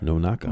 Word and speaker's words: Nonaka: Nonaka. – You say Masazo? – Masazo. – Ah. – Nonaka. – Nonaka.